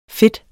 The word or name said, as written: fede